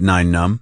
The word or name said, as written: Nien Nunb